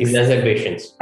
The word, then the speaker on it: exacerbations: spontaneous exasper exacerbed. Ex- oh my God, I cannot pronounce the word. Um, ex Ex- exacerbations.